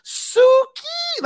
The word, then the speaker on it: Suki: No, it's Suki. Like the pronunciation of the way they did it in the sub, Suki desu ne.